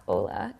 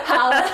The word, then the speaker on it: Hala: It's not Ola. It's Hala, right? – Hala. – Hala. – Hala. It's like holler without the ER.